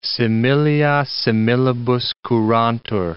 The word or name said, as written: similia similibus curantur